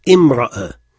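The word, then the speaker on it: imra'a (امرأة): Mais si le mot al-mar'a devait perdre l'article pour être indéfini, il y a un alif qui apparaît. Et on prononce imra'a. Mais on n'écrit pas de hamza. Imra'a.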